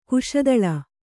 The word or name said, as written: ಕಸ್ತಲೆ